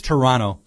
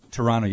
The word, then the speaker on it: Toronto: Okay, yeah, it's Toronto. Toronto, you don't pronounce the T.